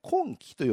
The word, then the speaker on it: 金亀: これ白鷺城という別名がついておりますけれども、彦根城にも金の亀、金亀と読むんですけれども、金亀城というふうになっております。